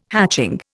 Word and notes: hatching: Indian

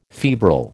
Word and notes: febrile: American English

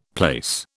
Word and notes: plaice: British English

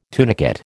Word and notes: tunicate: American English

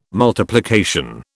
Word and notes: multiplication: British English

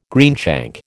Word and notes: greenshank: American English

